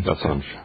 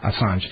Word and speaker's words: Assange: We're speaking with, you know, I still don't have the last name pronunciation. – Assange. – Assange. Julian Assange from WikiLeaks.